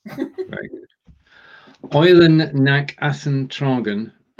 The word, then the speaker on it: Athen: Yeah, except in German it's Athen.